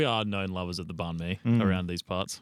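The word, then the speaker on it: bánh mì: And how, like banh mi.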